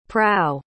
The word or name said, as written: prau